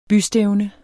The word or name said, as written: bystævne